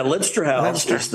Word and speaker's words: Leinster House: And one of the things, and help me with the pronunciation, it's the Leinster House, is that correct? – Yeah, Leinster House it was a former country house in Dublin, but is now the seat of the Irish Parliament.